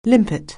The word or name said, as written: limpet